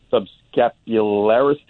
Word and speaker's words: subscapularis: I've got a significant labral tear and a partial tear of the subscapularis tendon. Is that, am I saying that right? – You are indeed.